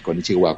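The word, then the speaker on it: konnichiwa (こんにちは): Sí, konnichiwa es hola. Es un poco largo, ¿no?, para decir hola, pero sí, es konnichiwa.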